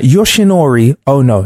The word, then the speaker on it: Yoshinori Ono: Yoshinori Ono.